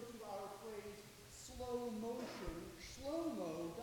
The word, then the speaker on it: Shlomo: But the way that we say his name, Solomon, is taken from the Greek. The Hebrew pronunciation is not Solomon, but rather Shlomo, which